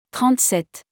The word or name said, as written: 37